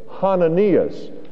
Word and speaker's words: Hananias: Actually, in the Greek, we have what we call a rough breathing in front of the name that we commonly call Ananias. And if you say that correctly, it's Hananias.